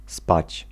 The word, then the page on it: dormir: /dɔʁ.miʁ/; France